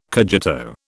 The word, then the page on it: cogito: British English